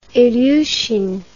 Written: ilyushin

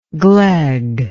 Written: glegness

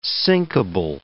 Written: sinkable

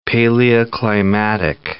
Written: paleoclimatic

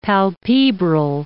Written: palpebral

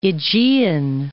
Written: Aegean Islands

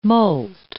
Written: molt

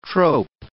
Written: trope

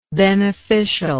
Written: beneficialness

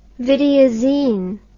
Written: videozine

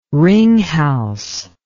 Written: ringhals

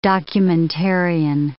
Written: documentarian

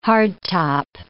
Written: hardtop

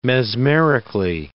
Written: mesmerically

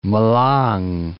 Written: Malang

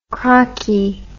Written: crocky

